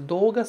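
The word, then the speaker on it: Doga (どうが): Repara que a gente fala do, mas a gente pronuncia do. Doga. Tá escrito D-O-U, você pronuncia D-O-O.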